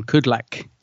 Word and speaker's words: Kudlak: Kudlak.